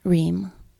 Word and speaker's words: Reum: His last name, R-E-U-M. How do you say that? – Reem. – Reem.